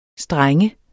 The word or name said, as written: streng